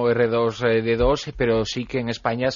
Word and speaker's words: R2-D2: R2-D2, en inglés.